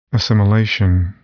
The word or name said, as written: assimilation